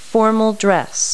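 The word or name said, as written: formal dress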